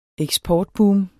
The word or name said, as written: eksportboom